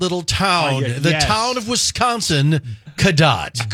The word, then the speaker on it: Cadott: I mispronounced it too, but it's Cadott.